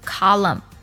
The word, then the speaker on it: column: 还有比如说像柱子 column，同样呢也是字母 n 不发音。